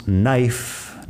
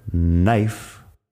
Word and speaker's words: knife: Luego tenemos knife de cuchillo, knife, con la K al principio que no suena.